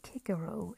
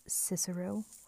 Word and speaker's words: Cicero: Or I should say Kikero, if we were to pronounce his name correctly according to how the ancient Romans would address him.